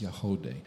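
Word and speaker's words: Yagody: YAG. O-D-Y, but it's pronounced Yahode.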